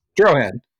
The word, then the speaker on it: Drohan: Is it Drone? Drohan? – Drohan.